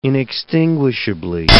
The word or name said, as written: inextinguishably